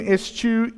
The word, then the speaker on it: eschew: He says, let him eschew evil. And yes, that is how it is pronounced. I checked. If you wondered. But he says, let him eschew evil and do good.